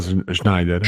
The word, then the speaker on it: Schneider: penso che sia la pronuncia giusta, Schneider.